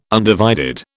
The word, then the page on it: undivided: British English